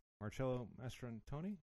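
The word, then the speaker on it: Marcello Mastroianni: Yes, and this was originally supposed to be portrayed by, or it was written for, I'm going to butcher the name, Marcello Mastrantoni? – Yeah. – Is that right? – That's right.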